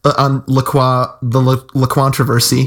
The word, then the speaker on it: LaCroix: So we had LaQuantroversy, you know, we heard it's supposed to be pronounced LaCroix. The company says it's supposed to rhyme with enjoy.